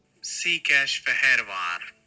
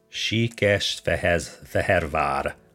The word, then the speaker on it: Székesfehérvár: And also, Székesfehérvár. – Székesfehérvár, Hungary.